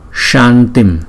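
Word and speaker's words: Shantim (शान्तिम्): Và chữ S có dấu sắc chúng ta phải đọc là S, như chữ sugar trong chữ đường của tiếng Anh đó. Shantim, shantim, shantim, krte, krte, krte.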